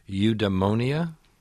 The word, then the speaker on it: Eudaimonia: And you've created a fictional country called Eudaimonia? – Yes, and good pronunciation.